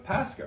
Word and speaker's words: Pascha: Okay, maybe we eat Pascha and chocolate. You think so? – And Pascha, the polar bear. – Polar bear, Casca the polar bear, rhymes with Pascha, right?